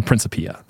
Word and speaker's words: Principia: It's known as the Principia. That's like how it's said.